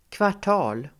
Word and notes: kvartal: [kvar_t'a:l]

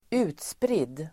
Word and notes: utspridd: [²'u:tsprid:]

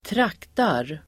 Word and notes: traktar: [²trak:tar]

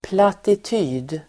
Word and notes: plattityd: platit'y:d

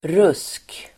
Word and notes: rusk: [rus:k]

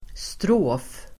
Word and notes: strof: [strå:f]

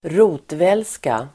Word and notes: rotvälska: [²r'o:tvels:ka]

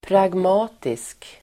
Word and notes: pragmatisk: [pragm'a:tisk]

